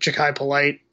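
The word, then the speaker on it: Jachai Polite: The drafting of, I always get his name wrong, but Ja'Kai Polite. – Yeah, you got it right.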